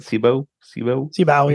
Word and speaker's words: Cibao: Cibao Valley, is that how you say it? Cibao, yeah.